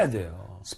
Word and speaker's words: read: 네, read의 과거는 red지만 예, 발음만 바뀌지 아하. – 스펠링은 같습니다.